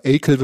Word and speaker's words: Achill: Das wird Achill geschrieben, also praktisch wie die Achillessehne, merke ich gerade, aber Achill wird es ausgesprochen.